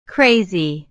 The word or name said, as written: crazy